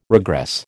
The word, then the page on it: regress: American English